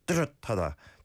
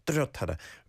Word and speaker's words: 뚜렷하다: 한국은 사계절이 뚜렷하다. – 뚜렷하다. 뚜렷하다.